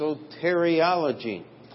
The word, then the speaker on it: soteriology: And if you like the big words, that's pronounced soteriology.